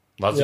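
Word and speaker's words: Vasilj: Aber der Torwart von St. Pauli, Vasili, der macht das, oder wie wird er ausgesprochen? Vasili? – Vasili. Ja, okay.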